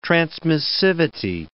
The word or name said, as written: transmissivity